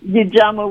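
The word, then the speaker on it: Ujamaa: Really impressed, with the work that you've done around, and I'm probably going to mispronounce this, the JAMA workbook. – The UJAMA workbook.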